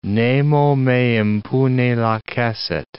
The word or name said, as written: nemo me impune lacessit